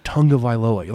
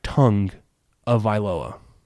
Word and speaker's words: Tagovailoa: It's not Tonga Vailoa. Like it's like Tongue of Vailoa.